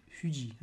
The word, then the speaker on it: Fuji: It's on the main train line between Tokyo and Kobe, and it's near Mount Fuji, or Fuji, as I was told to pronounce it, because they don't have the letter F.